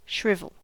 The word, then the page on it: shrivel: American English